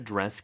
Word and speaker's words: dress: Is there a dress code? – 자, dress 스펠링 어떻게 되나요? – D-R-E-S-S. – 네, 그리고 우리는 드레스. 라고 발음하죠. 세 개의 음절로. 근데 영어는 네, 한 개밖에 없어요.